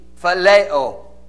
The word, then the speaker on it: phileo: And his response, thou knowest that I love thee, phileo, like Philadelphia, like brotherly love.